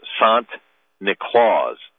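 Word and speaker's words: Sinterklaas: Well, the matter of fact, the name Santa Claus is the Dutch pronunciation of Saint Nicholas. So, Sant Niklaus, Saint Nicholas, and so you say Sant Niklaus a couple times. It's Santa Claus.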